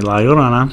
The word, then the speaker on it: La Llorona: Like, the donkey lady, La Llorona. Am I saying that right? – Yeah, La Llorona.